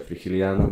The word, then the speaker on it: Frigiliana: hogy Frigiliana. – Frigiliana? – Frigiliana. – Frigiliana, igen. – A kiejtése Frigiliana.